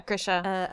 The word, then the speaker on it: Grisha: Yeah Grisha.